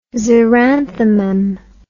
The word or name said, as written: xeranthemum